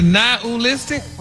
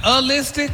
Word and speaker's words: nihilistic: But you actually pronounced it correctly the last time you said it. – Really? Nihilistic? – Wow. – Stop putting the ooh in there. – Not a-listic? – There you go.